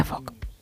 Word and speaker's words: folk: Su regla clave es que la letra L es muda. – Anda. – Con lo cual se pronuncia folk.